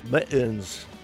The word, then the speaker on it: Mi'ens: In the background, this is Mittens. You pronounce this as Mittens, but take out the two T's and you got apostrophe there. As though instead of Manchester, you're in London, it's Mittens.